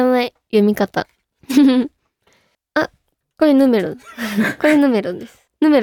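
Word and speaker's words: N°21: N ドットって読むと思います。これは N.21 え、なんかでも見たことあります、このこれはこの並びは見たことあるけど、確かに読み方、正解お願いします。え、ヌメロ本当ですか、嘘だ、ヌメロなわけないよ。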